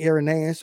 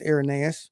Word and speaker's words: Irenaeus: Okay, so we come to a man, and I don't know exactly how to pronounce his name, so you have to forgive me, Irenaeus or Irenaeus. I think it's pronounced Irenaeus.